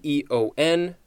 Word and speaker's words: aeon: Okay, this is A E O N or E O N, and it is pronounced eon.